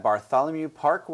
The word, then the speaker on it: Bartholomew Park: We're here at Bartholomew Park Winery. I said it right that time, didn't I? – Yeah.